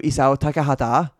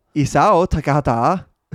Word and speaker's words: Isao Takahata: heter då Isao Takahata. – Eh, och han Bra då, uttal! – Ja, Säg det igen. Isao Takahata.